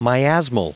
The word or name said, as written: miasmal